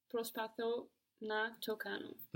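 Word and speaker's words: Prospatho (προσπαθώ): But with I try, we have the accent on the end. Prospatho. So there's a group of verbs in Greek that have their accents on the end, like this one, prospatho.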